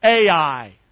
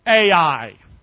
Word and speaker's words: Ai: Ai. In case you can't pronounce it, just say those two letters. Ai.